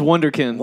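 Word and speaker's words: Wunderkind: How do you pronounce it? Wonderkind. – It's Wonderkind.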